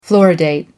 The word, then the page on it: fluoridate: American English